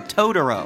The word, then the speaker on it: Totoro: My neighbor Totoro。 – トトロじゃん、隣トトロやん。 – Yes, Totoro, no, no, no, no, no, Totoro. – Totoro, T がね、あの D っぽく聞こえるのがいいですよ、 Totoro。